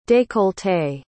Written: décolleté

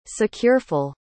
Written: secureful

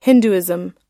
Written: hinduism

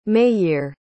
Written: mayir

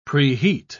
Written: preheat

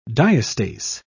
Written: diastase

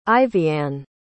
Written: Ivyanne

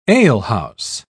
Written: alehouse

